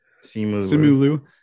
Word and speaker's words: Simu Liu: Simu Liu. – Yes. – Is that how we say his name? Right. – Simu Liu.